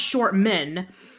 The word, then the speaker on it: Shortman: But yeah, well, I mean, like, yeah, but it's pronounced short men. So Shortman, it's kind of, yeah, it's Arnold Shortman.